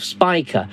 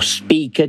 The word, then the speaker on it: Spica: On the 7th, it's at first quarter phase and lies above Spica, or Speaker, depending on how you want to pronounce it.